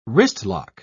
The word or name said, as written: wristlock